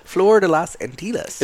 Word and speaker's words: Flor de las Antillas: Flor de las Antillas? Antillas? – Antillas? – Antillas? We're going to go with that. – Flor de las Antillas.